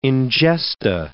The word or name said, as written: ingesta